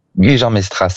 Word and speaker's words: Gujan-Mestras: Vous êtes à, allez, aidez-moi, Gujan-Mestras. – Gujan-Mestras, absolument, oui, sur le bassin d'Arcachon.